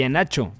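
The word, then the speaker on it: Iheanacho: es que la H se supone, tengo un amigo nigeriano y me dice, es que la H no se pronuncia, y Enacho.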